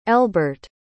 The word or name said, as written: elbert